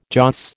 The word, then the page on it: joss: American English